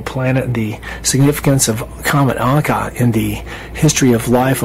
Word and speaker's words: Encke: That's E-N-C-K-E. And up until a few months ago, I was pronouncing it Inky, but my German friend, we saw the name on some kind of piece of gear on a sailboat with that same name, Encke, E-N-C-K-E. So she told me that that's how it's actually pronounced.